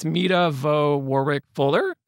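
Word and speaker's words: Meta Vaux Warrick Fuller: I think it's Mita Vo Warwick Fuller.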